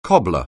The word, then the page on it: cobbler: anglais